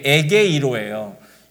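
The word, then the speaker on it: 에게이로예요 (ἐγείρω): 그래서 헬라어 발음하면 그게 에게이로예요.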